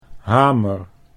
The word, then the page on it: hamer: Dutch